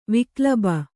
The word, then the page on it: ವಿಕ್ಲಬ: viklaba